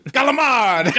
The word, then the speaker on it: calamari: Is that how you say it? – How do you say it? – Calamari? – Calamard! – Calamard! Eat all the calamard! – Oh, I forgot. Gotta say like the resident Italian. – You gotta drop the I if you're doing American Italian.